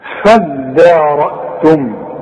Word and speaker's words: فادارأتم: نفسا فادارأتم، دي عايزه منك فن في النطق، ما تقولش فض فضى، الدال الدال والمد بتاعها مرقق، هتقول فداء، عارف على وزن ايه فدان؟